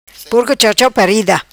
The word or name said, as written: Porco chau chau parida